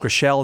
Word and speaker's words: Gröschel: Father Benedict Gröschel. – Gröschel, is that the proper? – That's how you pronounce it.